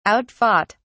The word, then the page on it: outfought: British English